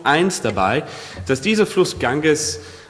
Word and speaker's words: Ganges: 2013 versammelten sich etwa 120 Millionen Menschen, um sich im Fluss Ganges, spricht man es so aus? Ganges? Ganges, okay.